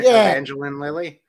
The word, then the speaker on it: Evangeline Lilly: Evangeline Lilly.